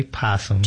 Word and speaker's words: opossum: You have a possum in the studio, and some people like to call it an opossum, but the O is silent, correct? – Right. You can just say possum.